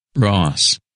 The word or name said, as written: Ross